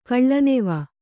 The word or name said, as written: ಕಳ್ಳನೆವ